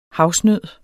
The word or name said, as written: havsnød